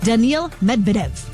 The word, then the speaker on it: Daniil Medvedev: He is headed to the quarterfinals, defeating 7-seed Daniil Medvedev.